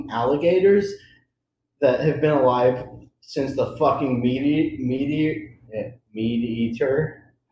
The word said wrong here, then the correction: meteor: How do you say that? Meteor. Sorry, my bad.